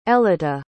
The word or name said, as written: elyta